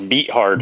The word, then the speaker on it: Beathard: It's pronounced Beathard.